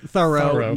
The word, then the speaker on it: Thoreau: This here's aspiring, and I'll explain that we know from the pronunciation in contemporary, the way people wrote his name in contemporary times, it was pronounced Thoreau, not Thoreau. That's why we're saying this with the emphasis.